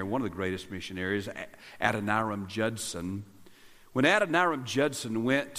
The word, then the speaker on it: Adoniram: Now, some of you have stumbled over the correct pronunciation of the name of Pastor Jeff's youngest son. His name is Adoniram.